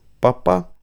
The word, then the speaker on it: Папа: Папа», — она сделала ударение на последнем слоге, — «действительно будет сердиться».